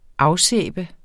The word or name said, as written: afsæbe